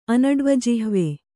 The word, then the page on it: ಅನಡ್ವಜಿಹ್ವೆ: Kannada